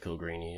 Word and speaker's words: Coolgreany: Is Cool Greeny, is that right? Or do you not know that one? – How do you spell it? – Cool Greeny. COOLGREANY. – I would have said Cool Greeny, yeah.